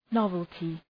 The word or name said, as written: novelty